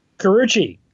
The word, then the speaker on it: Carucci: Carucci.